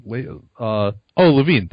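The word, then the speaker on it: Zach LaVine: But Zach Lavin, is that how you pronounce his name? Levine, that's right. Yeah, it's Zach Lavin.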